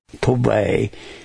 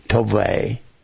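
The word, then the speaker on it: tov: Good is the word T-O-W-B. Tovay. It means, some pronounce it T-O-V-E, Tovay.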